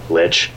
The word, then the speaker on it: lich: I mean, lich. Like rich. R-I-C-H is pronounced rich, right?